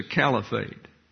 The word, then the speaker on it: caliphate: The Catholic Church has always had an eye on global dominance, while Islam's desire is a global caliphate. Now notice on the networks, they're calling this a caliphate. The Oxford American Dictionary, the American Heritage Dictionary, Merriam-Webster's Collegiate Dictionary have a long A there. It's a caliphate.